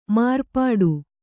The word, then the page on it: ಮಾರ್ಪಾಟು: mārpāṭu